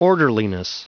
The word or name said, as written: orderliness